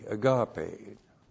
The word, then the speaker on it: agape: One of those words is the Greek word agape, A-G-A-P, long E, agape.